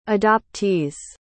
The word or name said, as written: adoptees